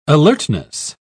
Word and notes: alertness: British English

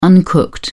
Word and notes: uncooked: British English

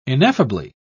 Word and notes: ineffably: British English

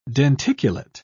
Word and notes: denticulate: British English

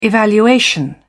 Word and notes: evaluation: British English

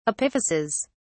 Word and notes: epiphyses: British English

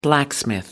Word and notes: blacksmith: American English

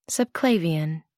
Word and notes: subclavian: American English

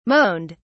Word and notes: moaned: British English

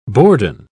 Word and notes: bourdon: British English

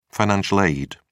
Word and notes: financial aid: British English

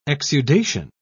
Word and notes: exudation: British English

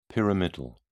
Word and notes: pyramidal: British English

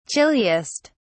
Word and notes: chilliest: British English

